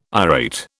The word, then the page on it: irate: British English